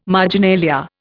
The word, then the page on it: marginalia: Indian